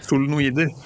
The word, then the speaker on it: solenoider: Og der har jo du også et annet prosjekt gående, med noen klangstaver og noen solenoider, eller hvordan man uttaler det, eller? – Ja, solenoider.